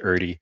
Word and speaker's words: Erde: It's German. My family has always said Erdi. I think the right way is Erde or something to that effect.